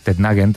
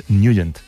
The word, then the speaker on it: Nugent: Hombre, siempre lo hemos llamado en el barrio Tetnagen o Tetnagen. – Claro, yo era de Tetnagen. – Pero la pronunciación correcta es Nugent.